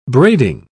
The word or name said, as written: braiding